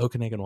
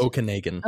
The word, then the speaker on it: Okanagan: Stay strong, Okanoga. Is that how it's pronounced? – Okanagan. – Okanagan sounds very like, let me go get my toboggan.